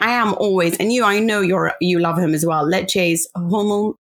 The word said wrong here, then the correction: Hjulmand: Oh, I never know how to say his name. Hjulmand.